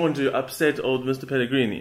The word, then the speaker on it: Pellegrini: The funniest part about that is that Mourinho now, for the third time, has intentionally mispronounced his name. So whenever, because people keep asking Mourinho about what did you think of his comments, and he goes, "Oh, this Pellegrino."